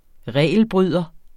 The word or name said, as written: regelbryder